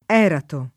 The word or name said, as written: Erato